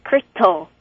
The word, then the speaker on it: Crito: It's Critol. – Critol. – C-R-I-T-O.